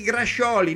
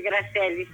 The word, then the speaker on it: grascioli: La pizza chi grascioli, no chi grascelli. – Ah, vedi, ecco, c'hai ragione, non si dice grasselli, si dice grascioli.